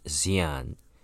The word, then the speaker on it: Xi'an: Okay, it's spelled X-I-A-N. It's just pronounced Sin, Xi'an. The X is pronounced with an S.